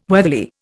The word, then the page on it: weatherly: Indian